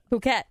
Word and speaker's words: Phuket: Number two was Tulum in Mexico, and number three was Phuket in Thailand. Am I saying that right, Phuket? – Phuket, yeah.